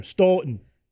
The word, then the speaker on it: Stoughton: You got to get the O in there. Stoughton. – They even get paid to say it wrong. – Stoughton. Got to say it like that, Ben.